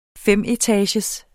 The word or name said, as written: femetagers